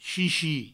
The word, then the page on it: chichi: provençal